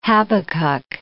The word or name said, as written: Habakkuk